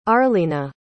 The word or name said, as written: arleena